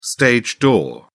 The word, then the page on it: stage door: British English